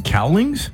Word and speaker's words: Cowlings: Al Collings. Am I saying his name wrong? – Cowlings? – Yeah, Cowlings.